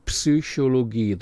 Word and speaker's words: Psychologie: Psychologie, the Y is pronounced like a U umlaut, an der Universität.